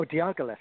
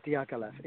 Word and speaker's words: Diaghilev: Oh, Diaghilev. – D-I-A. – Yeah, Diaghilev imagery, yeah.